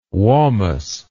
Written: wamus

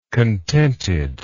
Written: contentedness

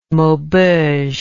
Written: maubeuge

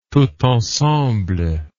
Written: tout ensemble

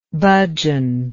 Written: bhajan

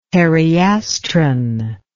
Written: periastron

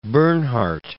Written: Bernhardt

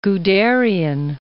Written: Guderian

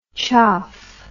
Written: choff

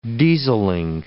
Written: dieseling

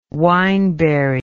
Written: wineberry

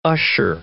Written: usher

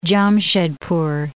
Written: Jamshedpur

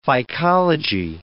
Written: phycology

